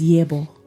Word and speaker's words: Yebo: The title of this beautiful song is Yebo by Giti. Am I saying it right? – Correct.